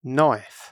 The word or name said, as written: knife